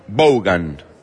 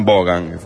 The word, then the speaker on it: Vaughan: Se escribe de una forma distinta a cómo se pronuncia. Es Iván Vaughan. B corta a U-G-H-A-N. Así se escribe. Iván Vaughan.